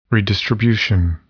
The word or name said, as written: redistribution